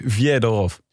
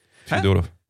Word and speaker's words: Fyodorov: Maar het is met een J ook. Fyodorov. – Ja, de E in het Russisch is een J. Fyodorov.